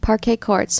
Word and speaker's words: Parquet Courts: Okay, so Brother Sports rhymes with? – Parquet courts. – So yes. Like, is that like parquet flooring? – Mm-hmm, yeah. – But on basketball courts? – Yes, exactly.